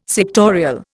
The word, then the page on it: sectorial: Indian